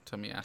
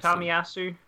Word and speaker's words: Tomiyasu: Can anyone give me the pronunciation? Tomiyasu? – Tomiyasu. – Tomiyasu.